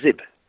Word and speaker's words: Zyb: I got this correct. It is Zib. Ed Bussey from Zib posted and said it's a ZYB is a Zib.